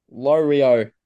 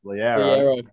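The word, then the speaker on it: Loiero: And this is how you say his last name, apparently. Lorio. How's that? – Oreos. – An Oreo. Okay. Is that even right? Do I even, because, you know, I do a radio show. How to pronounce it? Should I trust that cow? Did it get it right? – Trent Liero. – Liero. – No, I'm never using that website again. That messed me up.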